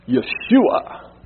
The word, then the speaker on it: Yeshua: I like to say Yeshua. That's how you say it in the Hebrews.